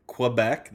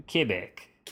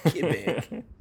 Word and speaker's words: Quebec: So when I say Quebec, that's wrong. – Quebec. – Do I have to say it like that? Quebec. – With a slight French-Canadian accent.